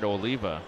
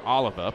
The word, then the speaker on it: Oliva: Two down for Jared Oliva. Or Oliva, pardon me, that's the way his family pronounces it.